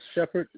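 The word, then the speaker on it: Shepard: Shepherd 60472.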